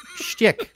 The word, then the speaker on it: shtick: That's his whole stick. – His stick? – Shtick. – There you go. – His whole shtick. What do you mean to pronounce every syllable and vowel and every word? I don't have time for that. – I think stick can lead people down the wrong path.